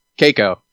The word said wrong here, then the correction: Checco: Keiko, sorry. – That's okay. For real, that's Chico.